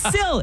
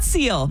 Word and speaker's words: sill: For the first time ever, it's sill. It's not seal.